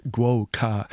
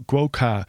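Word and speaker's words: Gwo Ka: And it's called Guo Ka. And it is spelt G-W-O, separate word, K-A. So Guo Ka is very much based on percussion and around the sound of a variety of drums.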